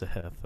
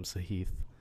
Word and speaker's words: Sahith: I saw some of the commentators getting called out on Twitter because his name's pronounced Sahith, and they kept calling him Sahith.